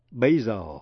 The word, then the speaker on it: basil: Đánh vần B-A-S-I-L-I-C-A. Nhưng chữ basil, B-A-S-I-L, basil là tên một thứ rau húng quế. Nhớ là phụ âm S trong basil đọc là z.